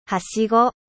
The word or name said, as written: echelle